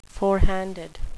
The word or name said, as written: forehanded